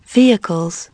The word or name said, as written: vehicles